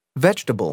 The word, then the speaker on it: vegetable: The vowel immediately after a stressed syllable is sometimes not pronounced. – Average. – Different. – Separate. – Comfortable. – Interesting. – Vegetable.